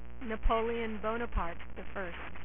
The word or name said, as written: Napoleon Bonafrog